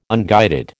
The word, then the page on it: unguided: American English